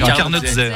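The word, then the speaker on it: carnotzet: Alors, elle a du mal, hein, parce que ça s'écrit TZ, mais nous, on dit. C'est comme un D et un Z, en fait. – Oui, un Carnotzé.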